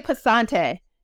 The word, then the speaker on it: Passante: I want to say Passante.